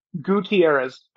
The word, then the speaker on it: Gutierrez: It's Gutierrez, but it's okay.